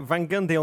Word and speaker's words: Van Gundy: Van Gundy only rhymes with Ted Bundy in my mind, so I'm going to steer away from that conversation.